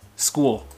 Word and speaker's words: school: phải phát âm được cái từ cái âm cuối của cái, cái từ ấy ra một cái chuẩn school school đó là âm l cong l cong lưỡi đấy experts.